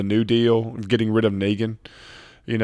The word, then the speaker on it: Negan: Well, Kirkman came out and said it's pronounced Negan.